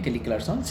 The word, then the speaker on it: Kelly Clarkson: ¿Sí dije bien Kelly Clarkson? sí Yo lo dije bien Big Kelly Carson tiene una frase que dice, yo nada más me hice famosa para olvidarte.